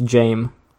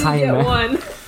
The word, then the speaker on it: Jaime: I thought it's Jame. – Jame. – James Jaime. – Jaime. – Jaime is literally like a Spanish name that is spelled like the same way Jamie is spelled.